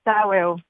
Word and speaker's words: Taweo: mais Tao, c'est comme ça qu'on prononce? – Taweo, oui.